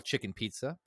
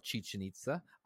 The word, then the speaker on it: Chichen Itza: I like to call it chicken pizza, but it's called Chichen Itza. I just couldn't pronounce the word Chichen Itza.